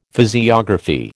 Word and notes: physiography: American English

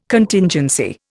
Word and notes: Contingency: Indian

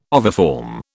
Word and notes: oviform: British English

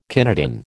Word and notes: kinetin: American English

